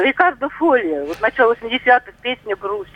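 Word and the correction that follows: Fogli: Э, Рикардо Фольи, ну а почему не вписывается?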